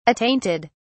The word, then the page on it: attainted: British English